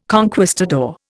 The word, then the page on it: conquistador: Indian